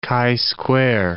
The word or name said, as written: chi-square